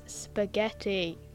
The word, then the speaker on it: spaghetti: It's not biscotti, it's spaghetti.